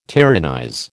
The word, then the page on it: tyrannize: American English